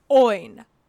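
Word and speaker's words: Óin: So the emphasis is on the O. Oin.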